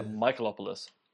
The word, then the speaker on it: Michalopoulos: It's Michalopoulos. So sounds like Michalopoulos. – Yeah, Michalopoulos. Yeah,